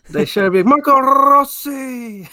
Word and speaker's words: Rossi: He's like, well, technically you roll an R there. So it's Rossi.